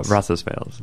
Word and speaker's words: Roncesvalles: but Roncesvalles. – Roncesvalles.